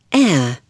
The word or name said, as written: air